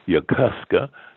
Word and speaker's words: Yokosuka: And as we moved in, from the Japanese pronunciation, Yokosuka, the Marine Corps, Yokosuka.